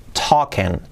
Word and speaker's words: talking: Cuidado que no es talking, hablando, Talking, talking. La N no se pronuncia y es ahí como una E. Y la G tampoco se pronuncia.